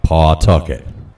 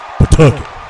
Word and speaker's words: Pawtucket: Okay, these out-of-staters always seem to pronounce the W, Pawtucket. True Rhode Islanders lose the W. They just go, Pawtucket.